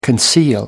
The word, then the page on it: conceal: British English